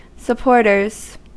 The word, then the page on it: adhérents: [lɛz‿a.de.ʁɑ̃]; /a.de.ʁɑ̃/; France